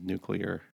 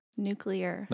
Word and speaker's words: nuclear: It is nuclear. – Nuclear. – Not nuclear.